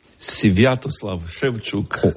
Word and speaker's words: Sviatoslav Shevchuk: Nos recibe literalmente en su casa su beatitud Stanislav Shevchuk. ¿Lo he dicho bien? – Sviatoslav Shevchuk. – No he dado ni una. ¿Cómo es? – Sviatoslav Shevchuk. – Sviatoslav Shevchuk. – Perfecto.